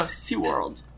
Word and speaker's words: SeaWorld: Não, SeaWorld. Porque o D no finalzinho é mudo.